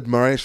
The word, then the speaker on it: Moraes: I said Moraes fine because I had to pronounce the Portuguese, right?